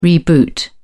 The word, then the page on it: reboot: British English